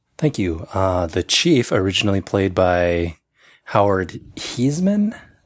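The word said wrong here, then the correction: Hesseman: I think that's how it's pronounced. Hessman. – Yep.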